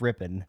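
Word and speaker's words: Rypien: It's pronounced Ripon.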